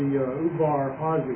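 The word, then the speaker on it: Udvar-Hazy: And the first one's at the Ubar Hazi, is the way I'm told it's pronounced, in the museum.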